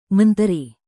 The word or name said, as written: menteri